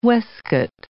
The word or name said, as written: weskit